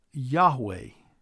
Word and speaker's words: Yahweh: Most Hebrew scholars believe that it is probably pronounced Yahweh, Y-A-H-W-E-H.